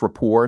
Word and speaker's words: Report: It's pronounced rapport, not report. – Right. I learned that. Right. No T. Right. – Right. – The T is silent. – The T is totally silent, as it is in Colbert.